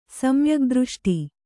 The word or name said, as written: ಸಮ್ಯಗ್ದøಷ್ಟಿ